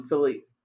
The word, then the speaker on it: Felipe: So he handed over the monarchy to his son, King Felipe.